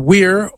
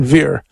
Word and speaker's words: vir: Like, you can pronounce the word V-I-R, which means man, weir, or, veer. I use the Italian or the church pronunciation veer.